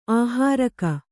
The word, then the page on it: ಆಹಾರಕ: āhāraka